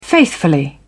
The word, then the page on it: faithfully: British English